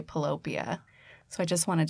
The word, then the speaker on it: Pillowpia: It's actually pronounced Pillopia. – Pillopia. – Like utopia. Like a heaven of pillows, a place, your happy place.